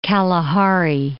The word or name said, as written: Kalahari Desert